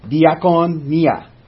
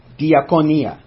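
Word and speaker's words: diaconia (διακονία): And it's the Greek word pronounced diaconia, diaconia, which means to wait as a servant or to aid.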